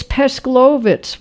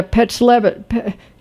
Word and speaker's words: Pesklovitz: No, it's Pesklovitz was her name. Levitz. Pesklovitz was her birth name. – Oh, Pesklovitz. – Yeah, okay. – P-E-S-K. And then, yeah, Pesklovitz. I'll try to pronounce it again. Pesklovitz.